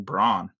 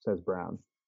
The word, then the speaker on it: Braun: So his brother plays for Mizzou, and I always pronounce his last name Braun. – I thought it was Braun. Every broadcaster, everybody here talks says Brown. Even though it's spelled like Braun.